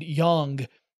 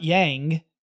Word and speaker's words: Yang: And that's where I learned that the right pronunciation was that ah sound, not an ah sound. So like, she had a colleague named Yang, not Yang. It's spelled Y-A-N-G, but Yang.